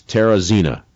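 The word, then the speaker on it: Teresina: and it's Terrazina. Did I do that right? – That is correct.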